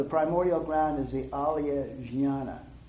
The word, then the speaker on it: jnana: And that we distinguish that from the greater alaya, which is called alaya jnana. And that's spelled alaya, and then a second word, J-N-A-N-A.